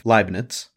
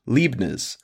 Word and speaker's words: Leibniz: So from what I could find, there's actually two ways to pronounce this man's name, the American and the British version. So for the American, we have Leibniz, and for British, we have Leibniz.